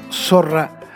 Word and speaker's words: zorra: Para entender el peso del término zorra, vale la pena pronunciar la palabra con la Z española, no con la Z liviana que utilizamos los latinoamericanos.